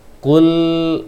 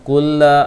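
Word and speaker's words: Kul (قل): Dibacanya yang tepat adalah Kul a'udzu bi rabbin nas. Jadi jangan baca kulla a'u, terlalu lemah kulla, karena lisannya lebih sering keluar, ataupun tidak pun keluar, terlalu cepat bacaannya barangkali.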